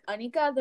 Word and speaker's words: Anika: But, like, if you know I like it being pronounced the proper way, like, Anika, then please say it that way.